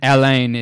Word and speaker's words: Alain: Johannes is spelled J-O-H-A-N-N-E-S. – There it is. – It's very French. And then Alain is A-L-A-I-N.